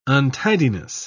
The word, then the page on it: untidiness: British English